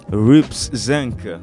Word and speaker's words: Rups Zinc: Et lui, c'est MC Rups Zinc. Rups Zinc, ça s'écrit R-U-P-S-Z-I-N-C. Rups Zinc.